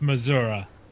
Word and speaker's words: Missouri: It's pronounced Missouri. – Oh, I'm sorry. – No, the people I used to work with someone from there, and they always like, "Oh, it's Missouri, not Missouri."